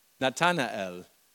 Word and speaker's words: Nathanael: Und sie geschickte mit, äh, na, muss dieses, dieses Namen gut aussprechen, Nathaniel. Nathaniel. Nathanael. Nathaniel, Nathanael.